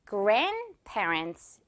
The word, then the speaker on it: grandparents: 역시 grandparents가 아니라 이 D는 중간에 규칙상 없기 때문에 grandparents 이렇게 발음이다고요.